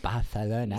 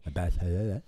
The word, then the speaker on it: Barcelona: I said Barcelona. – You said it correctly. – Barcelona.